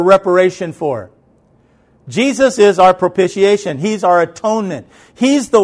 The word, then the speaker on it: propitiation: I've never known how to pronounce it correctly, so I finally looked it up in a dictionary on my phone, and I hit the play button to where it says to how to pronounce it. It's propitiation.